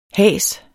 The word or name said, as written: has